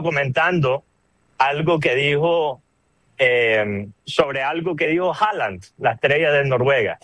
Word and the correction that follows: Haaland: O Holland, perdón, se me olvida que esa es la pronunciación correcta, Holland.